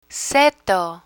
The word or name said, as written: seto